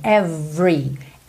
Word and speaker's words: every: É uma questão de ênfase na palavra, mas na grande maioria das vezes essa palavra não tem esse E do meio pronunciado. Então você pensa que o V já tá ligadinho no R. Every, every.